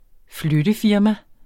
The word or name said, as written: flyttefirma